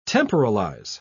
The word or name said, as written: temporalize